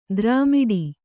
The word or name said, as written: ದ್ರಾಮಿಡಿ